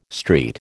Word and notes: street: American English

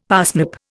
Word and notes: parsnip: Indian

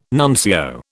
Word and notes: nuncio: British English

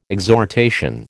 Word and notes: exhortation: American English

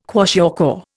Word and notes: kwashiorkor: Indian

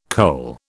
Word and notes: cole: British English

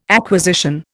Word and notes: acquisition: Indian

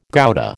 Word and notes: Gouda: American English

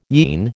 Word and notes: yean: American English

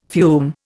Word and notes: fume: Indian

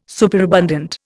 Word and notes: superabundant: Indian

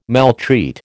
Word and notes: maltreat: American English

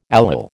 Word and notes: allyl: American English

